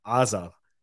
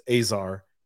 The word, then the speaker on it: Azar: So in Hebrew, it's Azar. But in English, it's Azar, and same thing.